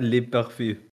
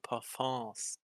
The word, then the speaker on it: parfums: Le Masculine Plural les parfüm. Und die richtige Aussprache ist immer ohne S. Den Deutschen ist das aber Wurst. Die knallen immer das S im Plural 3 dran.